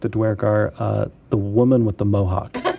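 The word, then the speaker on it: Duergar: I always pronounce it Dwergar. Is that wrong? – I do too. I like the way he pronounces it, but Dwergar. – I say Dwergar. – Dwergar. – But these are all made up words anyway. – Yeah. So I'm glad I got an ally though. So it's Dwergar.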